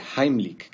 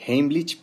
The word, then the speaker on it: Heimlich: Vamos a ver en este video la maniobra de Heimlich, que por cierto, esa es la pronunciación correcta porque está en alemán. La mayoría dice Heimlich, pero bueno, se entiende de qué se trata.